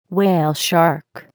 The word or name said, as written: whale shark